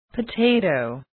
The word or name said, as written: potato